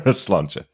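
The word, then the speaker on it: sláinte: Slauncher. That's how we pronounce it.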